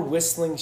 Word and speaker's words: Whistling Straits: Like, whistling. First off, the T is silent.